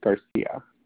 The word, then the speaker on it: Garcia: Landeros, that is spelled L-A-N-D-E-R-O-S, and it's hyphenated with Garcia, G-A-R-C-I-A.